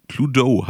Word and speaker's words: Cluedo: Ähm, das Ganze kam auch tatsächlich unter diesem alten Namen Cluedo, hattest du gesagt, spricht sich das richtig ja, aus, oder? Genau, genau.